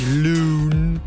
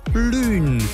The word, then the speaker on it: Lune: Also geschrieben wird sie L-U-N-E. Könnte man jetzt auf gut Deutsch einfach sagen, ja, heißt Lune. Ist aber nicht so. Es ist auch nicht, dass man die Geschichte englisch ausspricht. Das ist also auch nicht Loon ausgesprochen wird. Nee. Die Gute, die hat französisch klingende Wurzeln. Und deswegen ist auch ihr Name französisch klingend. Sie heißt Loon.